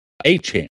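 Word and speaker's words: Etienne: It's like Achan. Like, very similar sounding to Devon Achan, but. – Okay. – Travis Achan Jr. Interesting.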